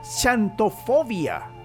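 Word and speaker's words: Xantofobia: Y es la Chantofobia Chantofobia. Chantofobia Con X. Con X. Chantofobia. Es una de las eh, quizá más inusuales que he dicho, Así. Ah, chantofobia.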